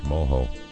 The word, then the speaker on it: mojo: It's spelled M-O-J-O. J-O, but pronounced Moho.